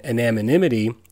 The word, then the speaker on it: anonymity: It's just like something happens when you take that and put it on the internet when there's an anonymity. Is that the word? Am I putting too many syllables in there? – No, you're right. – Anonymity. – You got it.